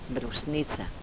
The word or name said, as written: brusnica